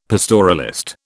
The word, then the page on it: pastoralist: British English